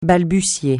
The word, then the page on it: balbutier: francais